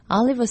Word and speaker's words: Oliver: Oliver 씨를 찾고 있습니다. O-L-I-V-E-R. 한국 분들은 많이 올리버라고 발음을 하시는데요. Oliver. 또 올리브 나무, 올리브 열매, 올리브 기름. Olive라고 합니다. O-L-I-V-E, Olive. O 발음이 아니라 A 발음에 가깝습니다.